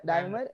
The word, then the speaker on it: Daimler: Because I think Daimar, sorry, how it's pronounced? Daimar. Daimler is